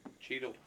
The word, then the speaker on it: Chytil: Cheadle. Okay. – Cheadle. Oh, oops. – Cheadle. – I thought it was Chytil. Oops. – No, it's Cheadle.